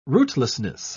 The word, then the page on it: rootlessness: British English